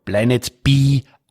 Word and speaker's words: Planet B: Vormittag um 11 Uhr die Science Busters for Kids und abends dann die Vorarlberg-Premiere von Planet B ab 20 Uhr. – Wie Planet B auf Englisch ausgesprochen heißt.